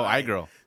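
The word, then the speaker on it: Ay Girl: Oh, I Girl. – So I'll tell you the title. It's spelled A Girl, but it's I Girl because it's A Y, but it's pronounced I Y E, right?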